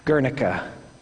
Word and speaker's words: Guernica: And at the same time, Revelation is far more like Guernica, the giant mural.